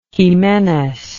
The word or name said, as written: ximenes